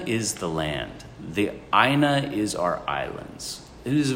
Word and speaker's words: aina: And how do you pronounce it? – A-I-N-A? – Mm-hmm. – Aina. – Okay. – Aina, right? Each vowel gets its own pronunciation in Hawaii, but some of them do blend.